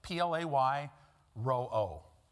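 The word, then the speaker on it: Play ro-o (πληρόω): It's the Greek word that would be pronounced play, P-L-A-Y, ro-o, R-O/R-O, or slash O. Play ro-o. It's not spelled that way. It's spelled P-R, or P-L-E-R-O-O.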